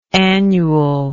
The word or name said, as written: annually